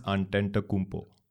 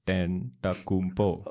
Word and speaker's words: Antetokounmpo: Giannis Antetokounmpo. – Can you repeat that? – Antetokounmpo.